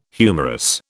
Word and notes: humerus: British English

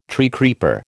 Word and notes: treecreeper: American English

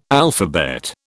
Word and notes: alphabet: British English

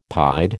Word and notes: pied: American English

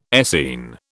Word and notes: Essene: British English

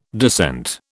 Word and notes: descent: British English

